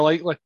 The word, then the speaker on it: Likely: I'm going to mispronounce his name. Ickley? Likely. That's a laddie. – Isaiah Likely.